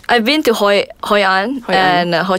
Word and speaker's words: Hoi An: Hoi An. I don't know if I'm pronouncing that right. – Yeah, you're right.